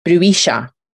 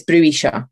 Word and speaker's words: bruixa: The Catalan word for witch is pronounced bruixa, according to Google Translate. That's how it pronounced it. I'm probably saying it with a slightly Fife accent, so I'm sure Catalan listeners would be like, not quite hen, in Catalan. But it's bruixa.